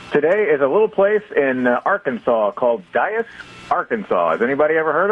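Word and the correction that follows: Dyess: D-Y-E-S-S. – No. – It's actually Dice, Arkansas, I think is how they say it.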